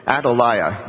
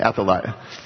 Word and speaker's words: Athaliah: Then Athaliah, and I know I'm saying that right, Athaliah, that's how you're supposed to say it, Athaliah.